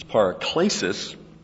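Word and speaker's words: paraklesis (παράκλησις): It's paraklesis, with this being a long E.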